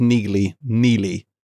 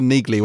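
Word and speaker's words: Neagley: It still upsets me they pronounce Neely. That's a silent G. I'm like, I've always pronounced her Neely when I've read the books, and it bothers me that it was Neely.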